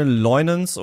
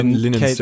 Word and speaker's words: Leunens: Was man noch sagen könnte, ist, das ist eine Adaption von Christine Leunens, oder vielleicht spricht man es auch amerikanisch aus. – Ich glaube, Linens, ja.